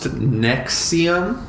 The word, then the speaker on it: NXIVM: It's pronounced Nexium. It's NXIVM.